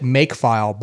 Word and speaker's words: mkfile: But specifically at the time, what I was looking for was the Mac OS version for, I'm going to pronounce it makefile, but it's mkfile.